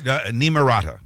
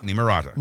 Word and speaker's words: Nimrata: It's Nimrata. – Nimrata. Is that right. How you pronounce it? Okay. – Nimrata.